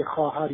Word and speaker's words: خواهر: مثلا در کلمه خواهر یا خواب حتی اون حرف وجود داره و اون حرف ساکته، اون حرف به تلفظ بر نمیاد در کلمه خواهر یا در کلمه خواب.